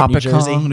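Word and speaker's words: Hopatcong: Hop It Kong, New Jersey.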